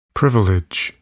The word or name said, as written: privilege